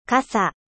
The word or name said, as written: parapluie